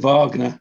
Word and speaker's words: Wagner: And Wagner. – There we go. That's how you pronounce them.